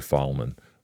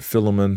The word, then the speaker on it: Philemon: And some people say Philemon, but Philemon, however you pronounce it, I know what you mean.